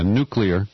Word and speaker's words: nuclear: For example, in Europe, because of the nuclear threat, did I say that right? – Yeah, nuclear.